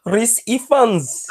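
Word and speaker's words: Ralph Fiennes: Oh, sorry, when you say his name, you need to say it properly. Reese Fans.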